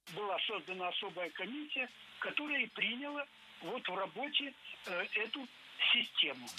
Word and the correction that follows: приняла: Не приняла, а приняла. Ударение в этом глаголе ставим на окончание.